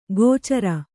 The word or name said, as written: ಗೋಚರ